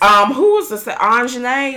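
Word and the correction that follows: Aunjanue: Is that how you say her name? – Anjanue Ellis.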